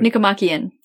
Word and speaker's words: Nicomachean: I'm going to say this wrong, but the Nicomachean Ethics, is that right? I didn't say that right. – Nicomachean. That's pretty close.